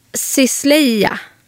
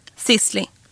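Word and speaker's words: Sisley: Om man säger Sisleia. – Sisli. – Nej, för det måste, nej. – Jo, man uttalar det bara så, fast det sås då.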